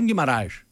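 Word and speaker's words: Guimarães: Πώς θέλετε να σας προφέρουν οι σχολιαστές σε όλον τον κόσμο? Του λέει σου Γκυμαράες. Ένα κακοφανίσιο τον Μπρούνο μου. Αφού έτσι θέλει να τον λάλο.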